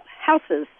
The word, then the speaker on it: houses: And all of a sudden, now everybody's talking about houses. Can you explain this? – So an S sound instead of what sounds like a Z. Houses, houses.